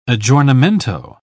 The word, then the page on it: aggiornamento: British English